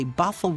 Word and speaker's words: Bothell: We have got Charlie calling from, I think I'm pronouncing it correctly, Bothell, Washington. Is that the correct pronunciation, Charlie? – Yes, that is.